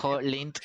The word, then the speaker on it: Koholint: Llega a una isla que se llama Koholit, Koholit, no sé cómo se pronuncia. – Koholint Island. Koholint Island.